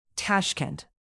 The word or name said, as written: Tashkent